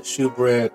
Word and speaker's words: shewbread: Now, some Bibles will say it's shewbread, and people will pronounce it shewbread, but when you have the word shew, S-H-E-W, it's basically a form of the word show. Like, I've shown you, I shew you, that kind of thing.